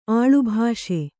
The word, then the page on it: ಆಳುಭಾಷೆ: āḷubhāṣe